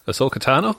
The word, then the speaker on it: Ahsoka Tano: Ahsoka Tano? – That's the one, yeah.